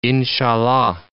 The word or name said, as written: inshallah